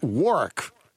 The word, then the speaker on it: Warwick: So, by the way, is it Warwick or Warwick? – It's pronounced Warwick to local people, so he's right.